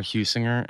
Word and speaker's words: Heusinger: Yeah, my last name is spelled H-E-U-S-I-N-G-E-R, Huesinger, and it's at Huesinger on Twitter, at Huesinger on Instagram.